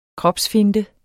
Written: kropsfinte

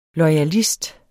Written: loyalist

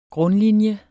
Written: grundlinje

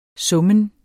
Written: summen

